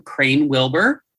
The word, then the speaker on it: Crane Wilbur: This name, I think I will be able to pronounce a little better than the other one, Crane Wilbur.